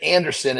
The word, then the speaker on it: Andersen: But anyway, my Instagram, it's the John Andersen. So, but keep in mind, my name is spelled differently. So it's J-O-N, and then Andersen is A-N-D-E-R-S-E-N.